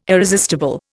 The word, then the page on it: irresistible: Indian